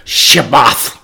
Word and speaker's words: Shabbat: If you look it up in the Hebrew, I'm going to say it like the Hebrews would say it, Shabbat. And that's our English Sabbath.